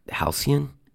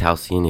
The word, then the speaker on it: halcyon: But I have to admit, I had to look up the word halcyon and how to say it. I guess halcyon is denoting a period of time in the past that was idyllically happy and peaceful. I didn't know that. But I looked it up and I learned how to say it. Halcyon. Halcyon. I can say halcyon now.